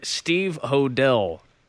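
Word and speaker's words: Steve Hodel: but we should get our guest on the line here, Steve Hodell. That's how we say his name, correct? – And yes, I believe so.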